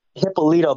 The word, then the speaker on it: Hipolito: It's Hippolito, but it's a lot of people say Hippolito.